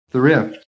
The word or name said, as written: THRIFT